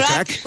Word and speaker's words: craic: Con lo cual ahí podremos, Sharon, decirte a ti y al resto de componentes de The Kors, what's the craic? Creo que lo he dicho bien. – What's the crack? – What's the crack? – What's the crack? – Es como el how are you, pero en irlandés, ¿vale? – What's the crack?